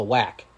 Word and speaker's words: WAC: How do you say it? WAC? – The WAC, it's the WAC.